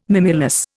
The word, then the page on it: mimulus: Indian